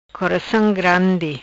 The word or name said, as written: Coracam Grandi